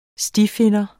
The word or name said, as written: stifinder